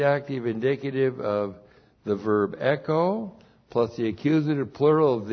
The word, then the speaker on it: echo: We start out with the verb echo. E-C-H long O. Echo.